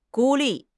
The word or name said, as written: 孤立